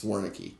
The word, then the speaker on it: Wernicke: I wanted to put the emphasis on the middle syllable instead of first, but it's Wernicke.